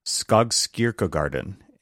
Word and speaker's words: Skogskyrkogården: We went to, try to say the name properly here, Skogskyrkogården.